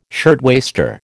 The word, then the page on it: shirtwaister: American English